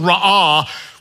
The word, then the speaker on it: ra'ah: And he looks at her, the Hebrew word that is used is the word ra'ah, which is pronounced this way. Ra'ah.